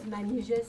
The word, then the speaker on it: Manijeh: My name is Manije Saba, M-A, if you want to spell it, M-A-N-I-J-E-H. The last name is Saba, S-A-B-A.